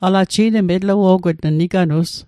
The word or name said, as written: niganus